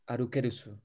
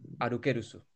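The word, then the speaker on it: arukerusu (アルケウス): Eh, en japonés, y ahora tiene sentido porque se llama Arceus, a pesar de estar escrito Arceus, se llama, eh, a ver si lo digo bien, ¿eh? Arus, arukerusu. Arukerusu. Bueno. o sea, según el canon se llama Arukerusu.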